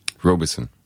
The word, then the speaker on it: Robison: Robison.